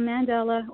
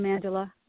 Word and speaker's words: mandala: So a mandala or a mandala, they're just different ways of pronouncing it, is a circle.